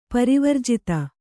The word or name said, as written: ಪರಿವರ್ಜಿತ